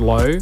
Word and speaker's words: LHO: Yeah, so before my name now is Low, right? So LHO, it's pronounced as Low.